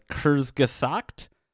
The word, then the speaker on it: Kurzgesagt: It's Kurzgesagt. So it's K U R Z G E S A G T.